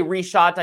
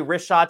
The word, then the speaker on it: Richat: But the only thing more epic than that is going to the so you say reshot, I say wrist-shot. Like I've been saying it wrong.